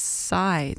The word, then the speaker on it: Sy3: But this is from a trio known as Psy. That one is spelled S-Y-3, and it's a recent release on the Music From Memory label, which is just reliably good.